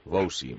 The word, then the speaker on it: Wouzee: Wouzee, vale. W-O-U-Z-E-E.